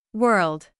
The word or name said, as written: world